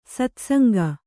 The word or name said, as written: ಸತ್ಸಂಗ